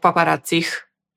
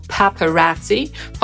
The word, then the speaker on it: paparazzi: Jak powiemy dwóch paparazzich po angielsku? Zacznijmy od wymowy tego słowa. Po brytyjsku przeczytamy to jako paparazzi, po amerykańsku paparazzi, czyli bardziej podobnie jak po polsku.